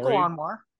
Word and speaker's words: Joko Anwar: Joko Anwar.